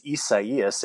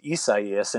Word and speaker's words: Isaias: The correct pronunciation of this hurricane is Isaias. And if you were to look at it, you might think it's Isaias, but it's pronounced Isaias.